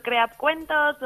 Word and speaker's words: Creappcuentos: Bueno, y nos vas a hablar de, em, a ver si lo digo bien, eh, eh crea Pepe Cuentos, ¿no? – Eso o Crea Cuentos o Crea Pepe Cuentos.